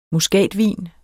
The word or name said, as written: MUSKATVIN